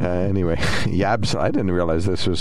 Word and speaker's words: Yabsley: So Yabsley. – Yabsley, all right. – I think I'm saying it right. Y-A-B-S-L-E-Y, Yabsley.